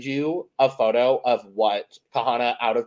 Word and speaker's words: Kahanna: This is like, if you're going to say her name, you got to say it right. It's Kahana.